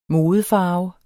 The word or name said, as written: MODEFARVEN